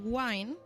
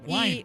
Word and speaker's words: Wine: Bueno, vengo a hablaros de un descubrimiento que hicieron Daryl Wine, no sé si lo he dicho bien, Dani. – Sí, lo has dicho. – Wine, como vino.